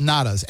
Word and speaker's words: natas: So I'm not going to pronounce it correctly because I can't do the Portuguese pronunciation, but they're called natas, N-A-T-A-S.